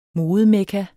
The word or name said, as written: modemekka